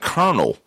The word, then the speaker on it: Colonel: And even to some extent, memorization stays a part of it, like kernel. You're not sounding Colonel out. You know what I'm saying? You remember that's the way Colonel is spelled and the way it's pronounced is, you know, when you see that those combination, you pronounce it Colonel.